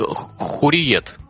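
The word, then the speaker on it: Hürriyet: Тут я извиняюсь заранее, но название произносится как Хурриет. – Mm-hmm. Хурриет.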